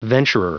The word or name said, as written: venturer